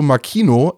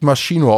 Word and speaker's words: Makino: Shuto Makino, er wird nicht Maschino ausgesprochen, habe ich mir extra nochmal sagen lassen von jemandem, der des japanischen mächtig ist.